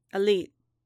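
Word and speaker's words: aliit: Elite, Din repeated dutifully. It sounded familiar. – The first syllable was the same as Alor.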